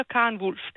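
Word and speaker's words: Karen Volf: Vi siger Karen Wulf, det gør jeg.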